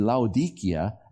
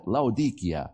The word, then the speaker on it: Laodicea: And so the town in the Turks, they still call it Laodicea. And we call it Laodicea. And they say, well, they had to teach their guides to mispronounce the town so we would understand what they were talking about. And they try hard to mispronounce it, just like we do. I'll call it Laodicea because that's what we all grew up calling it, though they called Laodicea.